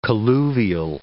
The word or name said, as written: colluvial